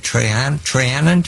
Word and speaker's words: Trianon: The treaty you picked out as being the most punitive of all was the Treaty of Trianon.